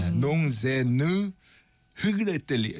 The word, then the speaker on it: Non, je ne regrette rien: Non, je ne regrette rien. 이게 알 발음이 히응하고 아라고 이 사이 발음이라 이게 되게 어려워요.